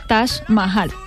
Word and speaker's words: Taj Mahal: Se escribe T-A-J. Y Mahal, M-A-H-A-L, Taj Mahal, así se pronuncia.